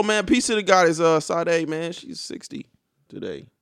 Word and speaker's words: Sade: Sade, yeah.